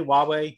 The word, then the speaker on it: Huawei: A lot of people do mispronounce it, but I do pronounce it properly. Huawei, located in Shenzhen, China, just outside of Hong Kong.